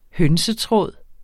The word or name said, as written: hønsetråd